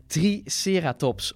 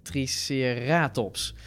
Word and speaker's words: Triceratops: Triceratops, of zoals jij altijd zegt, Triceratops. – Ja, ik zeg Triceratops. – Laten we dit consequent zo. Dat zei je vroeger, ja, dat is gewoon de klemtoon in het Nederlands.